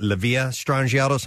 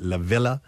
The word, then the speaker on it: La Via Strangiato: And I've always called it La Via Strangiato. Some people call it La Villa, but I call it La Via Strangiato.